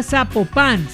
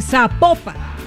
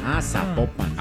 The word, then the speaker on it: Zapopan: Nos escriben los oyentes y que no se, no se pronuncia Zapopan, sino que se pronuncia Zapopan. – Zapopan.